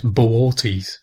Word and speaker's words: Boötes: And Arcturus is actually part of a constellation called the Herdsman, which, if I get this pronunciation correct, I hope I do, is Bootes, which is B-O-O-T-E-S, so it looks like booties or boots. But apparently you're supposed to pronounce the two O's separately, so it's Boötes.